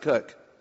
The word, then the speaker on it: Koch: My last name is spelled K-O-C-H. We pronounce it Cook.